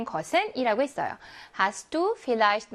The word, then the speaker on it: Vielleicht: 자, 그러면 Vielleicht V는 F 발음을 냅니다.